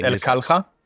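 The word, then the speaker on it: Al Kalha: The name of the restaurant is El Kalha.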